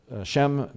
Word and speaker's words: Sham: Her name is Sham, S-H-A-M, but it's pronounced S-H-E-M, Shem.